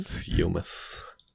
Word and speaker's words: Seumas: Die spielt äh, eine der Anwälte, dann, ähm, Alter, wie wird der Name denn ausgesprochen? Sumas? S-E-U-M-A-S. Sumas F. Sargent. Sumas.